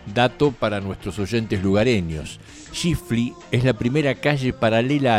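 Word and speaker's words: Tschiffely: Aguijoneado por la curiosidad, acudí a internet para investigar sobre esta combinación de nombre extraño, Aimé, y apellido impronunciable porque empieza con TS. Tshechifli, que así se pronuncia, sin la T al principio,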